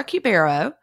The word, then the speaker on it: Cubero: Today, I'm here with Laura Cubero.